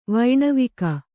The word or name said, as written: ವೈಣವಿಕ